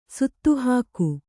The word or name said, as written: ಸುತ್ತುಹಾಕು